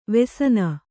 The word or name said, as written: ವೆಸನ